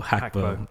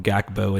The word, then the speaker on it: Gakpo: The announcers were calling him Gakpo, and he was like, no, it's H. Hakpo. – The G is silent.